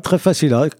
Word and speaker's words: Tréfacila: Salut les timbrés, aujourd'hui dans le timbre du coin, je reçois qui fait partie de l'association Tréfacila, je le prononce bien ? – Très bien, Tréfacila, comme le début de Tréfindel. – C'est ça, Tréfindel, Facila, comme la musique. – Comme la musique, exactement.